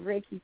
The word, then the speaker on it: Reiki: It's hard to know. It's Reiki. Two Japanese characters. It's a Japanese word. R-E-I-K-I. I remember Reiki. – It, how to pronounce it. I think of rays or light, and actually my first teacher was named Ray Golden, so that was I connected his name with the pronunciation.